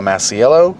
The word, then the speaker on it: Masiello: I post my pictures on Flickr under the name Michelle Masiello, spelled M-I-C-H-E-L-E. My last name is spelled M-A-S-I-E-L-L-O.